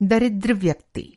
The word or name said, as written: दरिद्र